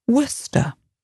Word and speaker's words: Worcester: And these place names end in C-E-S-T-E-R. But you don't pronounce the C-E bit. So examples, Worcester. W-O-R-C-E-S-T-E-R.